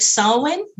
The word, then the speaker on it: Samhain: You pronounce it Samhain, but you spell S-A-M-H-A-I-N. Samhain. you spell it S-A-M. H-A-I-N. Uh-huh. You pronounce it Samhain. – Okay, so you have some silent consonants in there.